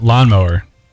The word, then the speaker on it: Lonmoore: It was spelled Lonmoore, but they all pronounced it Lawnmower.